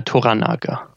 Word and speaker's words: Toranaga: Toranaga.